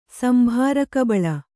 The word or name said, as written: ಸಂಭಾರಕಬಳ